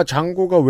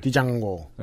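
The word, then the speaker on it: Django: 영화 장고가 왜 재밌는데요? 흔히 있는 일이 아니니까 재밌지. – 아 그래요? D는 묵음이어서 재밌는 줄. – 디장고. – 예, 드장고.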